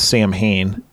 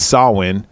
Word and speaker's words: Samhain: Like, this Samhain, which they, again, they pronounce Samhain in this fucking movie, right? But it's Samhain, is, like, the old, like, druid, which is, that's, like, Irish, isn't it? – I think so.